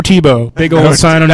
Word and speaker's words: Tebow: Because Tebow rhymes with TiVo.